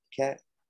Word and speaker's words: Paquette: is that how you pronounce paquette yeah that's what you were saying during uh,